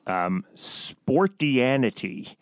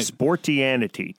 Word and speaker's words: Sportianity: It's also the title of your blog, Sportianity. Am I pronouncing that right? – You're pronouncing it right. – Sportianity.